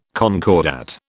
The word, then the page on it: concordat: British English